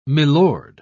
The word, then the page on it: milord: British English